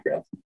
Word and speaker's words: Grantham: You know, my dad got to be Lord Grantham, and did I say that right, Grantham? – Yeah.